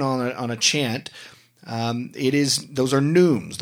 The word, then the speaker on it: neumes: These were called neumes. These were called neumes, not notes. And neumes is spelled N-E-U-M-E-S. That's neumes. So if you ever see a chant,